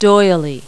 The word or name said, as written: doily